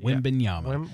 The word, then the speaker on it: Wembanyama: There's one good thing about Victor's name, and that is that it is pronounced exactly how it's spelled, Wembanyama.